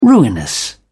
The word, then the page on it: ruinous: British English